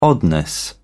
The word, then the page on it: oddness: British English